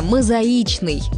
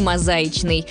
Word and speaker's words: мозаичный: Будем произносить его правильно – «мозаичный», никак не «мозаичный».